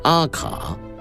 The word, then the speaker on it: AK: 提到阿卡四七，相信男同胞们一定不会感到陌生。在中国民间普遍把这款枪支称为 AK-47，然而其实这是一个误读。 AK 是俄文发音的缩写，音译为阿卡，直接按照英文字母读出来是错误的。